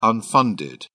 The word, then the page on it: unfunded: British English